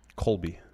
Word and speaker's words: Colby: It's Kobe Lopez. – Colby.